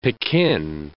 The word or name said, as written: Pekin